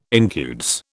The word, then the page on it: incudes: British English